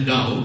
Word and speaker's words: Taoism: Taoism, meskipun tulisannya pakai T, Taoism, dibacanya Dao, Taoism, ini akar pemikiran tradisional, filosofis tradisional Cina, bahwa manusia memiliki dua jiwa yang disebut dengan Gun dan Po.